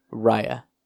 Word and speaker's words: Raya: and their names are spelled exactly the same, but that's the only reason I know, is because, like, my aunts pointed out that they're spelled the same, but they sound that name out different. So, okay, that's the only reason. One more time. Say it one more time. How it's pronounced? – Raya. I think it's Raya and the Last Dragon or something like that.